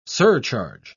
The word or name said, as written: surcharge